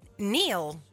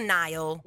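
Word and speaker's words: Niall: E si pronuncia però Neil, giusto? Non Nile. – Questo non te lo so dire. Come è Neil? È Neil.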